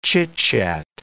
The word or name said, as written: chitchat